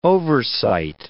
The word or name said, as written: oversight